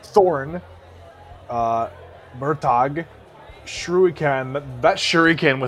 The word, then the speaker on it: Murtagh: With Murtagh, it's Mur rhymes with purr.